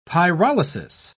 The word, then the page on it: pyrolysis: British English